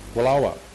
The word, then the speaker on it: Wallowa: I was thinking the other day, you know, many, many years ago, we did a mini-assembly in Wallowa. Is that how you pronounce that town? Yeah. Wallowa. That's close.